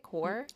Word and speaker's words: coir: And this can be a host of different things, shredded paper, shredded cardboard, or most commonly in the stackable systems is coconut coir. Is that how you say it? Core? – That's how I've always said it.